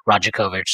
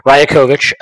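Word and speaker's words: Rajaković: very upset that I was mispronouncing his name as Rajakovic. So thank you for getting upset with me and correcting me. Rajakovic, Darko Rajakovic.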